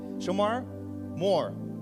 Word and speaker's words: Shemar Moore: Like, have you ever seen Shamar Lamar? How do we say that uh, his name? Shemar Moore. Shemar Moore.